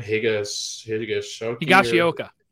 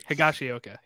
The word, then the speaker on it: Higashioka: Higashioka. – Thank you. – Higashioka.